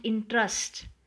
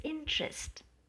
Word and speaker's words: interest: Mark the pronunciation of the word interest, not interest. The correct pronunciation is interest.